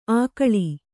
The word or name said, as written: ಆಕಳಿ